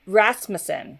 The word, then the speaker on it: Rasmussen: Did I say it right? – Rasmussen. – Rasmussen. Okay. – There you go.